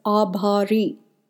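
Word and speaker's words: Abhari (आभारी): It's spelled as Dhanyawad, and the word grateful is commonly said as Abhari, Abhari. These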